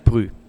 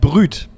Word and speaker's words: brut: Ja, pass auf, es heißt nicht Brü. – Oh. – Nein, es heißt Brüt.